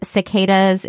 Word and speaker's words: cicadas: And cicadas is correct.